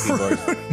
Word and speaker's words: Macron: He sent an official letter to French President Emmanuel Macron or Macron. I don't know how to pronounce Macron. – Macron. – You put some extra O's in there. It's fine.